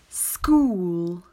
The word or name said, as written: School